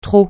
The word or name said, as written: trot